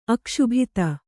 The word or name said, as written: ಅಕ್ಷುಭಿತ